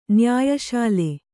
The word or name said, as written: ನ್ಯಾಯಶಾಲೆ